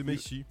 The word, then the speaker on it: Maisie: Ouais, à peu près, c'est Maisie, mais bon, ça va.